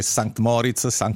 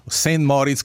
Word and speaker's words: Sankt Moritz: Noi abbiamo discusso tantissimo come pronunciare, per esempio, noi qui all'interno, come pronunciare Sankt Moritz, St. Moritz, come dicono gli italiani, dall'impressione che sia una località del, non so, del Colorado, invece che sia una località del Grigioni.